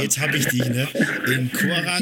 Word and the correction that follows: Karaganda: Nee, Karaganda, ja. Das ist Kasachstan. – Ah, Karaganda.